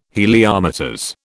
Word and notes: Heliometers: British English

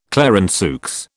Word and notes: Clarenceux: British English

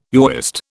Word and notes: Yahwist: British English

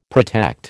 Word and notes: protect: American English

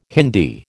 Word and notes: Hindi: American English

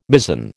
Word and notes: mizen: American English

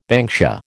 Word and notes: banksia: American English

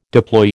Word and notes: deploy: American English

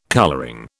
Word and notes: colouring: British English

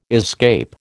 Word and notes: escape: American English

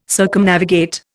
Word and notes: Circumnavigate: Indian

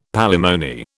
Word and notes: palimony: British English

